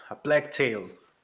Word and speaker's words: A Plague Tale: A Plague Tale.